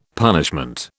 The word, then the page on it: punishment: British English